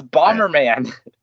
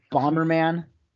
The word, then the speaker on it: Bomberman: It's Bomberman. – Bomberman.